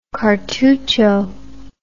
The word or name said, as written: cartucho